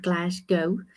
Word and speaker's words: Glasgow: I don't know if I say it right, Glasgow.